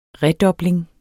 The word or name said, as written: redobling